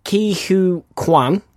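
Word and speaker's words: Ke Huy Quan: No, Ki Hu Kwan. – Yeah. – That's how you pronounce his name.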